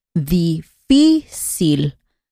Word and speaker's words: difícil: Como por ejemplo, difícil. Ahí la sílaba más fuerte es la penúltima y la palabra no termina ni en N, ni en S, ni en una vocal. Termina con la letra L.